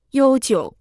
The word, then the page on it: 悠久: yōu jiǔ